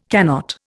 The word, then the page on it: cannot: Indian